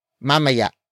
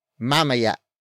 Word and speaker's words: mamaya: It is answered by the last word in this sentence, mamaya. Mamaya. Mamaya. Mamaya is spelled as M-A-M-A-Y-A. Note the glottal stop at the end.